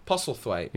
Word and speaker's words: Postlethwaite: Have I said it wrong again? Is it Postlethwaite? – Postlethwaite, yeah. – Postlethwaite.